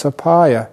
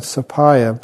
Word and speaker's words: sapaya: It's sapaya. You know, it's where we get the Thai word sabai. It's the same word, sapaya, but just, you know, got into the vernacular in the Thai pronunciation.